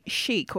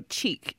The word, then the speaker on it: chic: And I noticed that recently you wrote a little story about Granny Chic or Cheek. How do you say? Chic. It's chic.